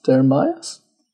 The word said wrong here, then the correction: Derimais: But it's just D-E-R-I-M-A-I-S. You say that then, Mr. Smarty Ball. – Dermas. – Eh? – Dermas. – D-E-R-I-M-A-I-S.